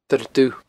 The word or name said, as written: Doire Dubh